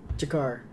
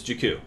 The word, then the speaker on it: Jakku: Jakar. – It's Jakku.